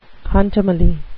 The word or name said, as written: contumely